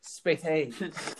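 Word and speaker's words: spatha: Spathas, is that what it's called? – The Roman short sword? – Yeah. Spathas. – It's pronounced Spathe.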